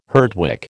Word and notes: Herdwick: American English

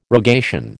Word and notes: rogation: American English

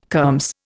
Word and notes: kermes: Indian

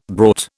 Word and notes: brought: British English

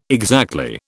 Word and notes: exactly: British English

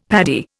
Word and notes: Paddy: Indian

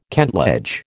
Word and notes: kentledge: American English

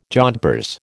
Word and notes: jodhpurs: American English

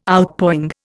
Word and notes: Outpouring: Indian

